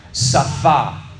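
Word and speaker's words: Safa (שפה): Safa. Safa. Yeah, we have a sin, a fe, and a hey.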